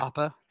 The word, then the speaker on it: Appa: Like, just is that Appa? Did I say that right? – Yeah. – Appa? Nailed it.